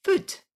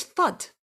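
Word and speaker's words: Füd: Now, how that is supposed to be pronounced is food. How that pronounces... – We assume. – And how that pronounces in my head every time I look at it is FUD.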